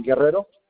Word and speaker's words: Guerrero: Guerrero.